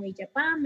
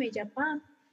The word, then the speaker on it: Iyapam: El nombre pues propiamente todo en inglés, pero pues lo podemos pronunciar como Iyapam, Iyapam, como ustedes gusten.